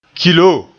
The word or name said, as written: kilo